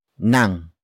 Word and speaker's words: ng: To differentiate between the two definitions, we usually emphasize which type of sermon it is by adding a possessive phrase such as the next word, nang. Nang. Nang. Nang is spelled as ng.